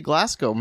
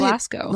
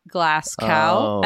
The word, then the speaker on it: Glasgow: It's obviously Glasgow. – I've heard you say Glasgow many times.